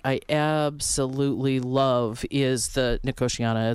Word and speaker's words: Nicotiana: Nicotiana. Mm-hmm. – Nicotiana. – Or Nicotinia, depends on who you are and how you pronounce it.